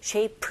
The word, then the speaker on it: shape: shape, shape, shape 아니라 앞에 에 강세 주셔서 shape 하시면 됩니다.